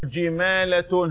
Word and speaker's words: جمالة: أقول جمالة هكذا، مفيش ألف بعد اللام.